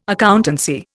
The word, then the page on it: accountancy: Indian